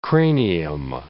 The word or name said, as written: cranium